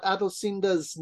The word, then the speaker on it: Adosinda: Uh, just real quickly, uh, Adosinda's name is spelled A D O S I N D A. – Adosinda.